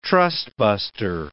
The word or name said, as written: trustbuster